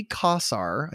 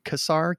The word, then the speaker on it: Kassar: At the time of our story, Nolan was no longer with the company, Nolan Bushnell, and Warner had appointed someone named Ray Kassar, I hope I'm saying his name right, Kassar, K-A-S-S-A-R, to take over.